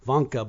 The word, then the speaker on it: Wanker: His name is Dr. Vanker, and pronounced Vanker, but it's spelled W-A-N-K-E-R.